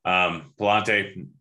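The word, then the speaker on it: Pallante: We got Alcantara going up against, I can never remember how to pronounce his name, Pinella. Palante, that's it.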